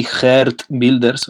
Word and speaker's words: Geert Wilders: Un Geert Wilders, que se pronuncia así, Geert Wilders, o sea, es todavía más feo de como ustedes se lo imaginan, la pronunciación,